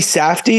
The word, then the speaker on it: Safdie: Benny Safdie is how you say his last name, but like I thought he really did a good job too.